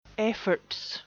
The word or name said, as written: efforts